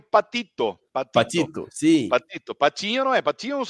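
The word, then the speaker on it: Patiño: Mas se pronuncia é, igual um pato pequeno aqui. Você fala exatamente igual um pato pequeno. É patinho, isso.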